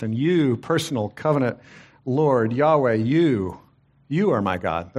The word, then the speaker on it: Yahweh: It's pronounced often Yahweh or Jehovah.